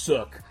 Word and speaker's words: Suk: And you and I both know that S-U-K does not spell the English word suck. But don't tell that to Philly fans when he's warming up in the bullpen. So, that's exactly right. – Please tell me that the guy's name is actually going to be pronounced Sook, and we'll be much better off.